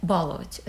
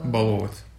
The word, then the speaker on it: баловать: То есть, ну, любовь не равно баловать. Это я, кстати, у меня очень сложно с э, ударением в этом слове баловать или баловать. Э, баловать. – Баловать, да. Будем говорить так.